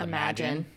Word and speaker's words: Imagen: It's spelled Imagen, but I believe it's called Imagen.